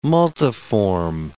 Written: multiform

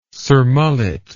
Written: surmullet

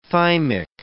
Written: thymic

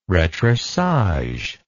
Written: retroussage

